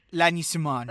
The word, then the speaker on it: Lagny-sur-Marne: Lagny-sur-Marne.